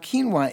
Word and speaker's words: quinoa: And the thing about quinoa is it's spelled one way and pronounced another way.